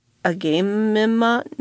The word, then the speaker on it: Agamemnon: the HMS Agamemnon, spelled A-G-A-M-E-M-N-O-N, Agamemnon, and the U.S.